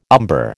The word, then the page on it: umber: American English